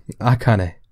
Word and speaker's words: Akane: It kind of felt like if Maeve decided after Akane, Ak- Akane how do you say it again? Akane. Akane.